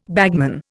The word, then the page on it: bagman: Indian